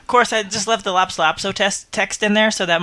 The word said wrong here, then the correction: Lorem ipsum: Did I say that right? – Lorem ipsum.